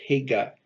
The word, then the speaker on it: Hega: You'll see it pronounced Haggai here, and you'll see it also as Hega, H-E-G-A, without the I.